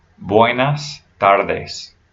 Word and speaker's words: buenas tardes: When you say good afternoon to somebody, it's buenas tardes. Buenas tardes. So have that s, that S sound at the end of buenas and the end of tardes.